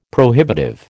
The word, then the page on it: prohibitive: American English